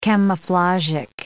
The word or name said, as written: camouflagic